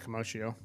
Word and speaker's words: commotio: and I'm going to butcher the first word, but it's like comatio. – I think it's commotio. – Commotio? – Yeah.